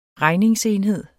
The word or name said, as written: regningsenhed